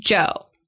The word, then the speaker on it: Zhou: But your last name is spelled Z-H-O-U, but it's pronounced Joe.